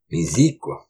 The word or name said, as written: Misiq